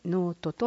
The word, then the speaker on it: 農人: 12 月 28 日、熊本ノートプロジェクト、農人と書いてノートと読みますが、茂見地区であり、親子や学生ら 24 人が参加しました。